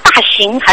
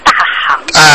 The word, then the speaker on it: 大行: 那么大行还是大行？ – 啊，大行。 – 那么大行普贤菩萨。 – 是念大行普贤菩萨。 – 对，怎么念大行了？ – 没有，我是念大行。 – 人行道的行。